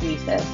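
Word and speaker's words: WiCyS: By the way, I love that it's pronounced WESIS. We and SIS. That's so clever.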